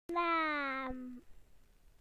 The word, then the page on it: name: British English